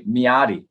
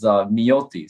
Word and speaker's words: Miody: So my company, Miody Partners Capital, so Americans, i.e. me, someone who's whitewashed, we say Miody, right? But the pronunciation is Miody.